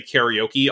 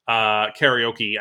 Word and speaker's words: karaoke: But so I may pronounce it both ways during the segment. It's generally sort of calcified as karaoke in American English.